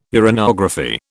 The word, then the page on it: uranography: British English